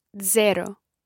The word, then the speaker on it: zero: Zero. – Zero. – Zero. – It's like a DZ sound at the beginning.